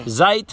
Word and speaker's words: Zeit: Echo der Zeit. – Zeit rhymes with kite.